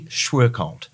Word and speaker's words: Schwerkolt: It's actually Schwerkolt, which is, I think, how you say it.